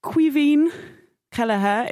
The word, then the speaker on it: Caoimhín Kelleher: Um, Caoimhín Kelleher is how we're pronouncing uh, that excellent gentleman's name.